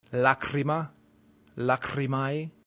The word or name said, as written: lacrima